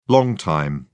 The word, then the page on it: long time: British English